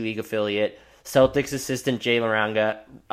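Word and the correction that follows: Larrañaga: I can't pronounce his name. Laranga? Man, that's bad. – Is it Laranega? I haven't seen that name. – Laranega, that sounds right.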